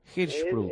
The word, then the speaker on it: Hirschsprung: UNG, ¿cómo se pronuncia? – Hirschsprung. – Bueno, tampoco es tan difícil. Hirschsprung. – Sí, pronunciarlo, leerlo es más difícil que pronunciarlo.